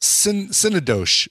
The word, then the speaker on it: Synecdoche: Synecdoche. That's how it's pronounced.